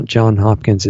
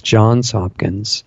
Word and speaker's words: Johns Hopkins: And yeah, Alex, you are mispronouncing that. Not that it matters, but it's not John Hopkins, it's Johns Hopkins.